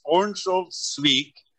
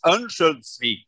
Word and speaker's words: Örnsköldsvik: In English, it's Örnsköldsvik, and in Swedish, it's Örnsköldsvik.